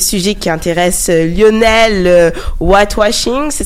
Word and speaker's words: whitewashing: Ça s'approche, oui, whitewashing. – Ok, whitewashing.